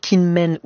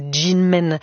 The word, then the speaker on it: Kinmen: On entend cette mémoire qui est encore très présente chez les habitants de l'archipel de Kinmen ou Jinmen, selon la prononciation, notamment parce que l'armée chinoise a tenté à plusieurs reprises de reprendre cette île si près de ses côtes.